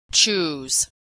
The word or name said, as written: choose